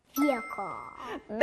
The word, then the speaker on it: vehicle: Vehicle. – 네, 잘했어요. 여기 가운데 silent H가 들어가요. Vehicle.